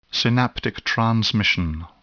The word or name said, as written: synaptic transmission